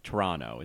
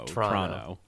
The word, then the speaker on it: Toronto: I know that you're not supposed to really pronounce the first O. It's Toronto. It's as if it's just T-R-O-N-O. Toronto.